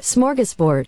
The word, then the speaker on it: smorgasbord: Smorgasbord. – Smorgasbord. – That's not right. – Smorgasbord. – No, if you say it like a true Scandinavian, it's smorgasbord.